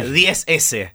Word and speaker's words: XS: Como XS en inglés, sí, pero se pronuncia 10S.